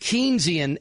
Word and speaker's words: Keynesian: You know, what I mispronounced before is Keynesian economics. It's always thrown me off. At least I've been consistent since college. I've always mispronounced it. I don't know why. I don't know if because if you look at it quickly, it looks like it's spelled Kenya, okay? And then it's but it's not. But Keynesian economics.